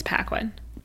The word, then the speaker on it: Paquin: Oh God, I'm going to butcher her last name. I've only seen it written, never heard it. Anna Paquin? Paquin? – I think it's Paquin.